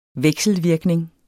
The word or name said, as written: vekselvirkning